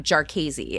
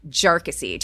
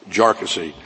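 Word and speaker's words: Jarkesy: And by the way, it's pronounced Jarkissi. Not a number of other ways that it's been pronounced by many.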